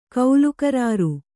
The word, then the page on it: ಕೌಲುಕರಾರು: kannada